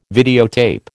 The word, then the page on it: videotape: American English